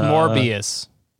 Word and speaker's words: Morbius: Mobius. I really like Mobius. – It's pronounced Morbius. – Morbius.